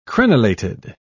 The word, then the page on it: crenelated: British English